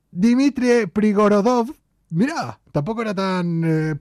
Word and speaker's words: Dmitry: Dmitry, no, se dice exactamente así, se pronuncia así en ruso. Dmitry, tendrías que...